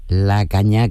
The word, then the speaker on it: La Caña: La Caña, que no se les olvide a nadie el nombre, que es como La Cañada, pero nosotros hablando en andaluz, La Caña con acento en la A.